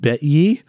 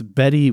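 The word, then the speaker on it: Bettye: On August 8th, one day later, 1969, Donald and Betty, but it's spelled Bet-ye. – Oh. – If we had to know someone named Betty that spells it Bet-ye, I would call her Bet-ye all the time. No, it's Betty Y-E.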